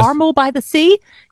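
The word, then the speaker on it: Carmel-by-the-Sea: Okay, in the city of Carmel by the Sea, am I saying that correctly? – Yes. – Carmel by the Sea, California, certain high heels require a permit.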